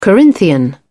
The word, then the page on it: corinthian: British English